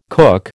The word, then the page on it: cook: American English